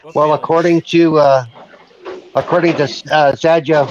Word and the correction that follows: Satya Nadella: whose name I can never pronounce, the head of Xbox or Microsoft. – Satya Nadella.